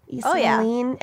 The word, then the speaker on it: East Moline: Before moving to East Moline, is that how you say it? – East Moline, Illinois.